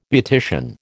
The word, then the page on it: beautician: American English